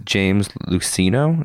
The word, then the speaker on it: James Luceno: That being said, the author, whose name I butcher all the time, is James Luceno. Is that how you say it? I don't know. Yep. – Nailed it.